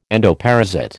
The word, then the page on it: endoparasite: American English